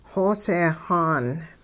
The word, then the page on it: xooseexaan: hupa